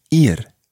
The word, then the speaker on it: ir: That verb is ir, ir. It's spelt I-R, and it means to go. – Ir. – Ir. – Ir. – Okay, good pronunciation there. Ir.